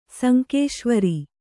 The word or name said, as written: ಸುಂಕೇಶ್ವರ